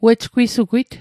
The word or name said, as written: wejgwisugwit